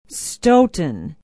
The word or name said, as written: Stoughton